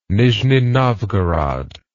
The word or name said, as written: nizhni novgorod